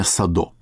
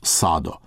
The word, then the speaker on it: Садо: Кстати, насчет ударения. Интернет дает ударение Садо. Я специально перед нашей программой созвонился с людьми, которые лично знали этого человека. И они настаивают ударение на «А» – Михаил Садо.